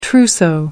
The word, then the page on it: trousseau: American English